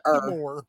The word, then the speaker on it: air: We have the thing for turning everything into er. – How do you spell it in St. Louis? – You still spell it A-I-R, but they pronounce it er. Nope. – That's funny. – It's still spelled A-I-R.